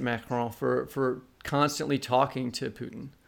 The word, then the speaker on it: Putin: By the way, we're going to use the American pronunciation of Putin. We're not going to say Poutine, which is what the French say. And Putin is a bad word in French.